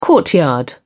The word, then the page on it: courtyard: British English